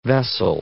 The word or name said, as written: vessel